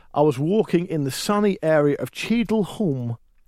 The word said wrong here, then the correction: Cheadle Hulme: I think that's how you That's pronounce it. No. Cheadle Hulme? Is that you say?